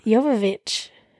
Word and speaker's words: Jovovich: It's Jovovich, I think. – What is it? – Jovovich. – Oh, okay. – I might be wrong too, but it's definitely not Jovovich.